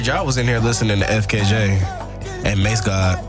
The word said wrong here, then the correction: Masego: Oh, Masego? – Yeah. Is that how you pronounce it? Masego? – Yeah.